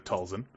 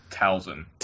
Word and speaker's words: Talzin: She's like the biggest Night Sister in the Clone Wars, and that's Mother Talzin. Did I say that right? – You got close. It's Talzin.